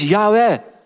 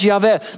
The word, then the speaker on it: Yahweh: Ze zeggen als zij daar de naam van God zien staan, daar staat JHWH in onze letters, dan zeggen wij dat is Yahweh. En ik denk dat dat het meest gebruikt is wel over de wereld, als het gaat over de naam van God, dat het wordt uitgesproken als Yahweh.